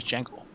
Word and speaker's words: Django: His name is Django. – The D is silent.